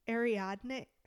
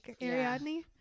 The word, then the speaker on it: Ariadne: So Ariadne, I always mispronounce her name. Ariadne.